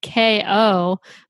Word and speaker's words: kayo: And the answer is K-O, but it's spelled K-A-Y-O, like mayo.